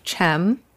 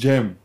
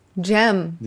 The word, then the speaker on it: Cem: And the first name you pronounce Jem. – Chem? – Jem. – Jem? Like with the G? – Like a Gemini, just Jem.